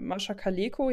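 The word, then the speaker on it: Mascha Kaléko: Ich habe nur, ähm, da stand noch, dass ähm, spannenderweise äh, 2018 die zwei bestverkauften Lyrikbände von Mascha Kaleko.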